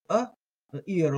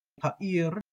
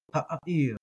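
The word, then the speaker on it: ha'ir (הָעִיר): Now remember with that aleph, the ayin, it's the sound, that guttural sound. So ha'ir. A lot of people will treat that as silent, and they'll do something like ha'ir. I pronounce it.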